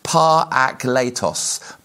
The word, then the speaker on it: parakletos: It's a Greek word and it's pronounced parakletos, parakletos, parakletos, some would refer to it.